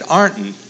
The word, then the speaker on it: Ironton: So when I got to Ironton, I found out how they pronounced it. They said Arnton.